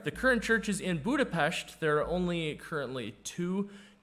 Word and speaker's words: Budapest: You're supposed to say Budapest, not Budapest. So when you see an S in the Hungarian language, you say it like an SH.